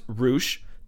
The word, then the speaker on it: Rush: Roosh, now that we know it's pronounced Roosh, thank you, Jason Linick, he just became the ROH Heavyweight Champion.